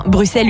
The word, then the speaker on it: Bruxelles: Non, on ne dit définitivement pas Bruxelles parce que ça vient du latin, du romain. Et donc ça ne se prononce pas X mais S. Donc c'est bien Bruxelles une bonne fois pour toutes.